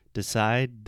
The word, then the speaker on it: decide: So, for example, in the word decide, the last letter is an E, right? Uh huh. But if you say the sound, decide, d, d, d, the last sound is a D. We don't pronounce the E at the end.